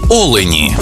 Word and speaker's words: олені: однині і в множині наголос падає на перший склад – олень та олені.